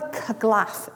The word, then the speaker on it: curglaff: But do you know what a Curglaff is? – A who? – A Curglaff. – Now, how would you spell it? – C-U-R-G-L-A-F-F.